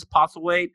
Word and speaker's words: Postlethwaite: Peter Postlethwaite is Postlethwaite.